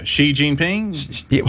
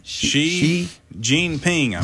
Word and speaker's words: Xi Jinping: Yeah, Xi Jinping. – How do you say his name? – Xi Jinping, pretty sure.